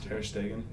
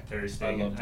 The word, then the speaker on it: Ter Stegen: And right now, I think it's I can never pronounce Ter Stegen. – Yeah, Ter Stegen.